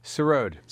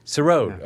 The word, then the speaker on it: sarod: And I want to say, am I saying it right? Sarod? – Sarod. – Sarod, yeah. Okay.